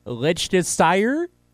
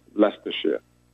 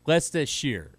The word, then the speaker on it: Leicestershire: Liechtenstein? – So the town is Melton Mowbray, but the county in England, even though it looks like it's spelled very strange, is actually just pronounced Leicestershire. – Leicestershire.